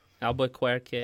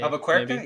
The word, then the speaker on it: Albuquerque: Oh my God, how do I say his last name? Albuquerque? – Albuquerque. – Albuquerque? Yeah, that sounds right.